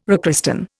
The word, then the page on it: rechristen: Indian